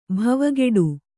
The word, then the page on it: ಭವಗೇಡಿ: Kannada; bhavagēḍi